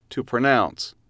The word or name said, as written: to pronounce